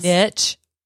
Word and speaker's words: niche: Niche. Do you say niche? – No, I say niche. – Niche. Okay, niche is right.